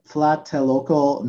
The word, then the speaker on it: Tlatelolco: It was the Tlatelolco massacre. It happened in the 1960s. – Oh, that was actually perfect pronunciation.